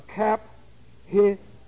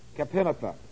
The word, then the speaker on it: Caphenatha: Now, this wall, and this is what I didn't have for you last week, this wall was called the Kap-Hi-Nepheia. Let me spell it for you. You might see it in reading of Jerusalem. C-A-P-H-E-N-A-T-H-A. Kap-Hi-Nepheia. And the purpose of this wall was to isolate the Syrians from the city in the city of David from this area.